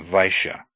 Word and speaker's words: Vaishya: AI, pronounced I, is like the AI in aisle, as in Vaishya, merchant.